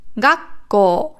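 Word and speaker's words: Gakko (学校): Also hier ist die Betonung auf dem ga und auf dem ko. Gakko. Nicht gakko. Also nicht hoch, sondern runter. Da da. Gakko. Hoch, runter. Gakko. Und lang natürlich.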